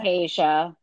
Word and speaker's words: Tayshia: And is it Tayshia? Is that how you say it? – Tayshia.